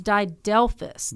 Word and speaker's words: didelphys: that's and I just completely butchered it. It's didelphys. – Didelphys. It. Yeah. D i d e l p h y s.